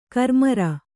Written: ಕರ್ಮೀರ

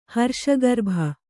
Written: ಹರ್ಷಗರ್ಭ

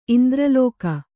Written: ಇಂದ್ರಲೋಕ

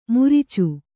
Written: ಮುರಿಚು

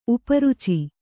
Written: ಉಪರುಚಿ

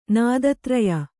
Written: ನಾದತ್ರಯ

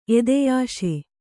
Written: ಎದೆಯಾಸೆ

